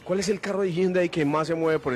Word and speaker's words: Hyundai: La pronunciación es con J, Hyundai.